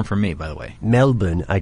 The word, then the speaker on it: Melbourne: Melbourne and Brisbane.